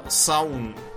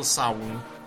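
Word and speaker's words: Samhain: типа Самайн. На самом деле, современные ирландцы говорят э, «саун». Да, пишется «самхайн», а произносится «саун».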